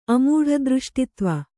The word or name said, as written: ಅಮೂಢದೃಷ್ಟಿತ್ವ